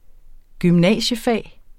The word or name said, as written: gymnasiefag